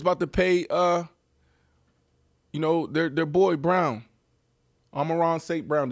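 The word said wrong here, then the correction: Amon-Ra St. Brown: Did I say that right? – Yeah. Amon Ra St. Brown. – Amon Ra St. Brown.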